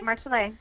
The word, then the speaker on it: Marcelais: And today we are sitting down with Marcelais Vineyards. Did I pronounce that correctly? – Yes. Perfect. Yes, that's right. Marcelais.